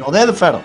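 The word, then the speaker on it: Oded Fehr: Bueno, el 23 del 11 del año 1970, este es nuevito, recién llegado a la familia, nace Obed Fer, no sé si también lo dije bien. Sí, Obed Fer. Ahí está, gracias.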